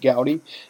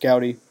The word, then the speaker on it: Gowdy: First off, I want to start with, why did I get the name Rowdy Mitch Gowdy? And I've had the name since I was about 3 years old. Obviously, it rhymes with Gowdy, Rowdy, Mitch Gowdy. Yep, it rhymes.